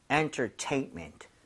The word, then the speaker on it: entertainment: entertainment도 여기 강세를 줘서 여러분 entertainment 이게 아니라 네? – 제가 두 번 들려드릴게요. 한번 같이 따라해 주세요. entertainment.